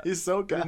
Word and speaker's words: Ahsoka: Ahsoka? That's how you say it? Yeah. – Yeah, Ahsoka.